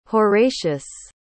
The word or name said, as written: horatius